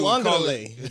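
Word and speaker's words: Uiagalelei: Where DJ Ugalele, am I saying that right? Ugalele, DJ Uangale. – Uangale. – Call it DJ U. – I always say like ukulele.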